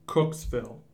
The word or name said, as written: Cooksville